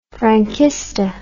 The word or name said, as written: franquista